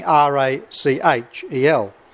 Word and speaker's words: Rachel: Uh, but in ecclesiastical Latin, when it comes before an, uh, an e, as it does here, the, the ch has a ch sound, as in uh, cherub and as uh, found in our English pronunciation of Rachel.